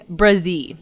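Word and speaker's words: Bresee: Did I say it right? – It's Brzee. Yeah.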